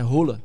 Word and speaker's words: Haaland: Sabia como é que é a pronúncia do nome dele? – Sim. – É Rola.